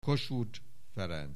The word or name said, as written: Kossuth Ferenc